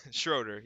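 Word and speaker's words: Schröder: I, I need to stop saying his name wrong. I, I just it freaks me out every time I say it. But SGA that, or Schroeder? – Schroeder, yeah, that guy.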